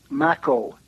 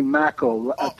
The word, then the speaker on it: Makow: Right, and then my name is Makow, Henry Makow. Rhymes with tobacco.